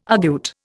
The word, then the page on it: argute: Indian